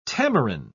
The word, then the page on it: tamarin: British English